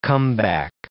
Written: comeback

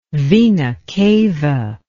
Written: vena cava

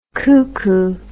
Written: coocoo

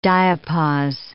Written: diapause

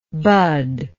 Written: budlike

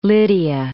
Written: Lydia

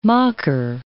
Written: makar

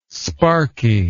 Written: sparky

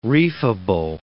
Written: reefable

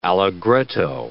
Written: allegretto

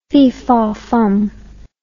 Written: fee faw fum

